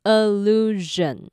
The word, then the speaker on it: illusion: 那要注意一下后面那个 s-i-o-n，它不要念成省，它是有点摩擦音的热的声音，所以它是念成 illusion。